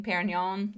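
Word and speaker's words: Pérignon: He's bought Dom Perrier champagne, Perignon. – Yeah. – I said it wrong too, just like Bill did at the beginning of the film.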